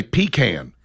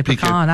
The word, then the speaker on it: pecan: And by that, I mean, when I say pecan, I've had people tell me, in the South, we say pecan. And so I say pecan. I don't care where I'm at. – I'll say pecan, and someone will say, oh, in the South, it's pronounced pecan.